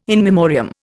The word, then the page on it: in memoriam: Indian